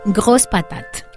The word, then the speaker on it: Grosse Patate: Y hablamos precisamente de la Grosse Patate. Dime si está bien la pronunciación. – Es Grosse Patate.